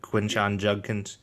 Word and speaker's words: Quinshon Judkins: I said that right, right? Quinshon Judkins. – Yeah.